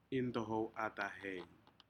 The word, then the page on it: intohou atahen: Aneityum